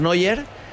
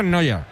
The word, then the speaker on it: Neuer: Yo digo, ese se pronuncia, es que es Neuer, pero se pronuncia casi igual, sí. – Sí, sí, se pronuncia en Noya.